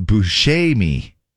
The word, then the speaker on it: Buscemi: Steve actually has been known to pronounce his last name Buscemi, but the proper Italian pronunciation is Buscemi.